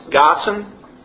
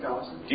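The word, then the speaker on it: Gaussen: Okay, through leading Lewis, is this Gason? – Gossen. – How do you say it? – Gossen.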